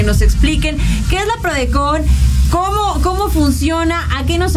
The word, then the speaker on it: PRODECON: Entonces, justamente existe la PRODECON. ¿Lo dije bien? – ¿PRODECON? – PRODECON. – PRODECON.